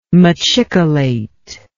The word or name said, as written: machicolate